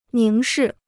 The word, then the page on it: 凝视: níng shì